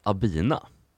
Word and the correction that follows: Abna: Det, Abna uttalas det. – Abna, oj.